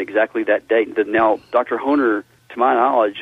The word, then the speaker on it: Hoener: Well, again, I mentioned the work of Dr. Harold Honer. His last name is spelled H-O-E-N-E-R, and I think he did his doctoral dissertation at Dallas Seminary on chronological aspects of the life of Christ.